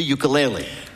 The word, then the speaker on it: ukulele: the ukulele.